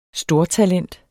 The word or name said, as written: stortalent